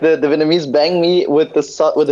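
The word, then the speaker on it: bánh mì: Well, I reckon it's like it's not banh mi, it's banh mi.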